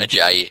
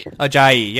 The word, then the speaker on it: Ajayi: Ajayi. – Ajayi, yeah. I always want to say Ajay or something, and then I get scared and don't say anything.